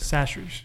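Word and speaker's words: Chachere's: Is it Chastries? How do you pronounce it? – Sastries. – No, I'm going to argue that too. – No. Tony Sastries. Sastries.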